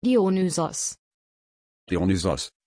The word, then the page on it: Dionysos: German